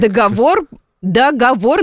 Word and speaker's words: договор: Нет, договор на последнюю букву О ударении.